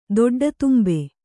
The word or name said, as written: ದೊಡ್ಡತುಂಬೆ